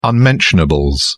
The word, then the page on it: unmentionables: British English